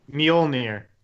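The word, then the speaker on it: Mjölnir: Mjolnir.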